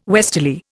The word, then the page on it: westerly: Indian